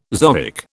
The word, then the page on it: zoic: British English